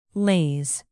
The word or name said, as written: laze